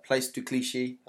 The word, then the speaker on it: Place de Clichy: And it turned out that on this street in France called Place de Clichy.